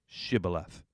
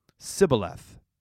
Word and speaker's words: shibboleth: challenged would-be crossers to say the Hebrew word shibboleth. In the dialogue of the Ephraimites, the word is pronounced with an initial S, not sh. So the Gileadites slew anyone who said Sibboleth.